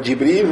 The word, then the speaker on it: Джибриль: То есть и также читается Джибраиль. Можно говорить Джибриль, можно говорить Джибраиль.